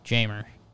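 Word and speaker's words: Jeimer: You pronounce the J. – You do? – Yeah, Jamer.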